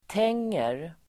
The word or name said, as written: tänger